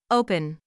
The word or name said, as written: open